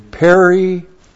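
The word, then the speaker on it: periaireo (περιαιρέω): Now the word to remove or to take away is periaireo. Looks like that in the Greek, P-E-R-I-A-I-R-E, long O.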